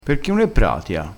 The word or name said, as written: granata